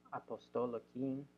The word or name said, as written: apostolikin